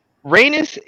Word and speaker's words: Rhaenys: Oh, she's Rhaenys.